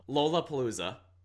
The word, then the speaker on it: Lollapalooza: He's done Lollapalooza. I mispronounced that so bad. – That was fascinating. I've never heard anybody say it like that. – Lollapalooza. Lollapalooza. – Hold on. Say Lola. Wow. – Lollapalooza. There we go.